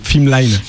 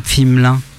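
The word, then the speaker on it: Pflimlin: Ok, et t'écris ça comment du coup, Fimelin ? Fimelin ? Mais avoue, là, c'est dur. – Donc, alors, tu notes P-F-I-M-L-I-N, comme ça se prononce.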